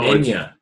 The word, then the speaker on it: Enya: I-N-G-A? – No, Enya.